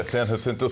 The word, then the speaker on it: San Jacinto: It's a great pronunciation on the name, by the way. – Jacinto. Thanks San to your Jacinto. coaching. Yeah, San Jacinto.